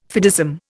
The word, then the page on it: fideism: Indian